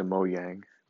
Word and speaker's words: Mojang: I would be the CEO of Mojang, and I would rename it to Mojang, because that is the correct pronunciation, apparently, I've been told.